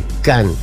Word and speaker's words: Khan: K-H-A-N, Can. De esa forma se escribe.